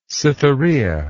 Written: cytherea

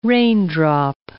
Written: raindrop

